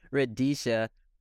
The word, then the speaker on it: Radicia: Chelsea Radicia, goalkeeper from Idaho State University.